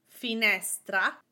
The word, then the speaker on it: finestra: In Italian, most words are stressed on the syllable right before the last one. For example, with fi, ne, stra, ne is the syllable before the last one, stra. And my accent goes on that ne. Fi, ne, stra. Finestra, window.